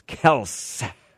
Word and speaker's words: Kelce: And fans want T-Swift married already to Travis Kelce. That's how you really pronounce it.